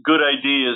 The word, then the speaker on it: GUDID: You say Good ID, it's GUDID, right? – Yeah. So that stands for the Global Unique Device Identification Database. And that specifically, Good ID is the way the FDA pronounces that.